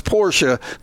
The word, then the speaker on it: Porsche: Just because 99.99999% of the population, including the manufacturer of the car itself, says it's pronounced Porsche, doesn't mean that you have to join that 0.000001% and rebel against it.